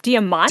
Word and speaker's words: Diamant: Is it Diamant? – Diamant. Diamant? Something like that. Yeah, it's Diamant.